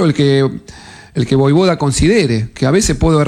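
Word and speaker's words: Vojvoda: Se pronuncia de esta manera. Que claro que sea Boivoda.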